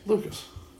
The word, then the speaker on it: Lucas: Lucas. – Luka Dean. – The French still pronounce a little S there, man.